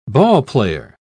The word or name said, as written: ballplayer